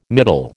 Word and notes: middle: American English

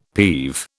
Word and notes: peeve: British English

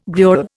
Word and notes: gruel: Indian

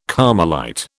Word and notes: Carmelite: British English

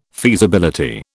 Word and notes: feasibility: British English

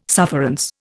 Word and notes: sufferance: Indian

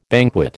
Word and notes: banquet: American English

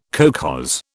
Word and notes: kolkhoz: British English

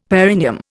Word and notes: perineum: Indian

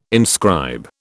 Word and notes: inscribe: British English

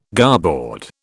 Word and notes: garboard: British English

